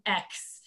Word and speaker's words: Margaux: My first name is the French way. So it's Margaux.